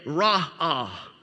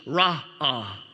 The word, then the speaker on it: ra'ah: Now, interesting, interestingly, here, that, that Hebrew word for see, that word is pronounced or said ra'ah. R A apostrophe dash A H, ra'ah. Okay, so it's kind of like uh, you like you like raw sushi. Ah, raw ah.